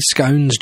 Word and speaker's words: scone: Jane said scone should rhyme with cone.